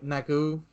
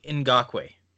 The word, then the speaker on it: Ngakoue: Yannick Ngakoue. – Yannick Ngakoue, yeah. – Yannick Ngakoue. – Thank you. Yannick Ngakoue. It's like Yama. It's like my Twitter account. Like, you barely pronounce the N.